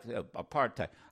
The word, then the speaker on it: apartheid: It rhymes with hate, by the way. See, y'all like apartheid.